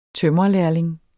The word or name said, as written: tømrerlærling